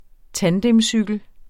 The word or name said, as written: tandemcykel